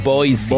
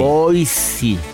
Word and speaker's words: Boise: ¿Cómo se pronuncia? Boise. Boise. Pero hay, Boys Boise, para, la raza.